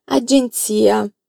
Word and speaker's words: agenzia: Attenzione alla pronuncia di agenzia. Dai, come si dice agenzia? Agenzia. Un'altra volta, che abbiamo la pronuncia come nella parola pizza.